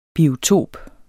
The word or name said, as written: biotop